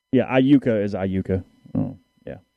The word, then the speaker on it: Iuka: Yeah, I don't even remember how you said it, but it's I like your I, a long U, and then ka, Iuka.